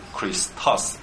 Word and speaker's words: Christos: 啊，所以你们又会了一个啊，希腊文 Christos，你们也会英文就是 Christ 啊，英文念成 Christ， C H R I S T O S， C H 念成 K 哈，其实那个 C H 应该是 X 哈，发出来的音是 K 的音，K Kri Chris Christos。 Christos，OK，Christos。